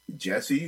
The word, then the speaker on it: Jeshy: Django, Earth Eater, Jesse, who, it's spelled J-E-S-H-Y, but that dude is amazing.